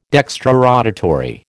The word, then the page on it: dextrorotatory: American English